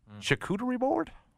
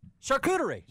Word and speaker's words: charcuterie: We had my son take, and I can never pronounce this correctly, charcuterie board? – Oh, yeah. Charcuterie.